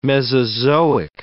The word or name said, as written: Mesozoic